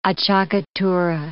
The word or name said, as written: acciaccatura